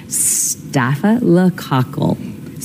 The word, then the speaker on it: staphylococcal: but it is pronounced staphylococcal.